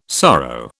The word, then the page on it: sorrow: British English